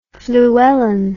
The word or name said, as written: fluellen